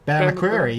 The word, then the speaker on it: McCreary: McCrory. Bear McCreary, yeah.